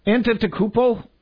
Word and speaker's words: Antetokounmpo: Antetokounmpo? – Oh, you just did it!